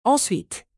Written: ensuite

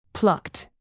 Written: plucked

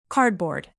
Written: cardboard

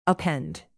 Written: append

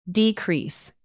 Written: decrease

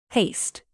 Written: paste

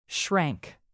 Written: shrank

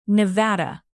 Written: nevada

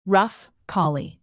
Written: rough collie

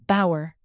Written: bower

